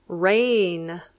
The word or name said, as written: rain